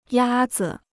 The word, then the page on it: 鸭子: yā zi